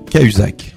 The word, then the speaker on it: Cahuzac: Casuac, si tu nous avais bien écouté, si tu peux encore le faire. – Reprononce-le pour voir. – J. Cahuzac. – Cahuzac. Oui. T'avais dit Casuac.